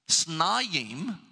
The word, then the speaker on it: Snayim: But this particular word is simply spelled S-N-Y-M. Depending on where you put the vowels, it means two different things. It can be pronounced Sanim, S-A-N-Y-M, which means crimson or scarlet, a color. Or Snayim, which means double or twice the portion that is normal.